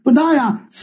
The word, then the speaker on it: 佛陀耶: 不答呀，写出来字是佛陀耶这三个字，念成不答呀。古代呢，佛陀耶三个字就念不答呀，现在普通话才读成佛陀耶了。